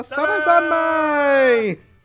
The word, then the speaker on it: Sarazanmai: Sarazanmai! You have to say it like that.